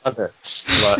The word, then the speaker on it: Lovitz: Lovitz. He has love in his name.